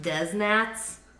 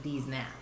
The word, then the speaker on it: Deznats: I think technically it's pronounced Des Nats, but it's spelled D-E-Z-N-A-T-S, so it's, you kind of want to pronounce it D's Nats.